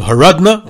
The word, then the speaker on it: Horodno: He was the son-in-law of another Grodno legend, Reb Nachumke of Horodno, which is Grodno in the Russian pronunciation, where they couldn't say the letter H, so H. Horodno became Grodno.